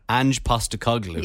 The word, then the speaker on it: Ange Postecoglou: Ange Postecoglou.